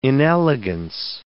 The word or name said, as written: inelegance